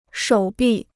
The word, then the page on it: 手臂: shǒu bì